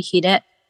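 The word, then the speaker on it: 鰭: はい、 Japan Hot 100 第 3 位はストーンズの暴れろ、第 2 位はスピッツの美しいヒレ、そして第 1 位は夜遊びのアイドルでした。 – ヒレって読むんだ。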